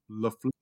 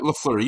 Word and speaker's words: LaFleur: So when it comes down to it, head coach Matt LaFleur, is that how you pronounce it? LaFleur? – Yep, LaFleur, you nailed it.